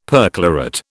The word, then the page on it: perchlorate: British English